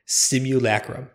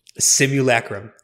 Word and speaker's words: simulacrum: I never learned how to say it. – I got you before getting to this. – Say that again. – Simulacrum. – Okay. I totally intended to and then forgot. And then I'm reading this and like I see like the blue link there. It's like, ah, fuck. I'm going to have to own up to not knowing how to say this. Simulacrum.